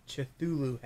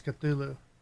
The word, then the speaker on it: Cthulhu: You can buy for $8.90 on Amazon a Cthulhu hat. – I think that's Cthulhu. – Cthulhu. Sorry, I pronounced it wrong.